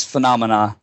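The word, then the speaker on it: Funomena: and then you went on to work at Funamina, correct? – Yeah, the pronunciation is phenomena, like phenomena, but, Okay.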